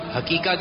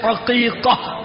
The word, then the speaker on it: عقیقہ: اچھا, امیر السنت کی بارگاہ میں عرضے, کچھ لوگ حقیقہ کہتے ہیں, حقیقہ, اس کا صحیح تلفظ کیا ہے؟ – حقیقہ. – ہاں سے عین سے, عین سے عقیقہ.